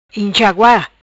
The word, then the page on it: Inxagua: Patuá